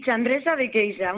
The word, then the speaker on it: Chandrexa de Queixa: ¿Se dice así? ¿Chandrexa de Queixa? – Sí, Chandresa de Queixa, muy bien.